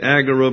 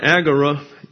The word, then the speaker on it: agora: It comes into the English as Agora in your dictionaries, and it's often mispronounced as the Agora. It's not an omega here. It's an omicron. So it can't be agora. It's agora. See, this, the omicron is like a short A sound.